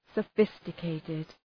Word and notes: sophisticated: sə’fıstə,keıtıd

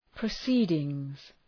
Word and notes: proceedings: prə'si:dıŋz

